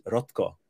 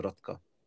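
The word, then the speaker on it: Rotxo: Okay, but the pronunciation is Rotko. Okay? That's how it's quiet. The X is silent, so Rotko.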